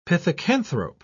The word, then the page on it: pithecanthrope: British English